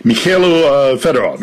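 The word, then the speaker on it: Mykhailo Fedorov: Who is and what's the situation with Mikhailo Fedorov? Am I saying that right? – Mikhailo Fedorov, yes.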